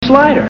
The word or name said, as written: slider